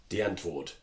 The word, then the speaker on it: Die Antwoord: The group Die Antwoord. – Die Antwoord.